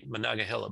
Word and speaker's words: Monongahela: I finally figured out how to say Monongahela, by the way. I think that's how you say it, Pennsylvania.